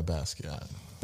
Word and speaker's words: Basquiat: Is it Jean or Jean Basquiat? – Yeah, Basquiat.